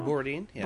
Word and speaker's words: Borodin: Borodin, yeah.